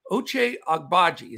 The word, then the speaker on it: Oche Agbaji: That's Oche Agbaji. I'll get it much more clear. Oche Agbaji. It's just a little tricky on the when you're pronouncing it, but I love this kid.